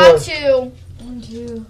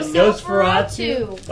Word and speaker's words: Nosferatu: No Sophoratu. – What? – No Sophoratu. – What the fuck are you talking about? – You talking about Nosferatu? Why are you pronouncing it like that? – Nosferatu?